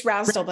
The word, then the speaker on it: Rasdal: Amy Rasdal, how do I say your last name? – Rasdal. It's Rasdal, but I am not picky Rasdal about it.